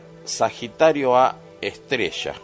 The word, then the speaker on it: Sagitario A*: Ustedes lo van a ver en los libros como Sagitario A con un asterisco a un costado a la derecha, que en realidad se pronuncia Sagitario A estrella.